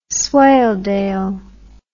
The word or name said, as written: swaledale